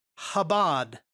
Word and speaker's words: Chabad: And you would get something like this, and you might pronounce it Chabad.